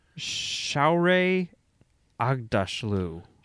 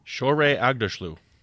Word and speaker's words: Shohreh Aghdashloo: Shohreh Aghdashloo. – Shohreh Aghdashloo.